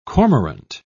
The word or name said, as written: cormorant